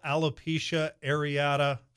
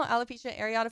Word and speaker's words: Alopecia Areata: And before we move on, just give a shout out to the National Alopecia Areata Foundation. Did I get that right? – Yes, you did. – Okay. – And you pronounced it right. National Alopecia Areata Foundation.